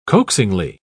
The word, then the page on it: coaxingly: British English